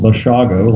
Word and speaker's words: Bashago: Just as a point of personal courtesy, my surname is pronounced Bashago, like Chicago.